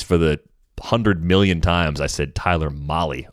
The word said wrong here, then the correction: Mahle: over the years. That was one that I just flat out had wrong. So thanks to the uh, pronunciation guide, it's Tyler Mahle, and it has been corrected.